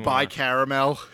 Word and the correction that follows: bicameral: Bicameral. – Oh, yeah. – I famously mispronounced it as bicaramel in high school.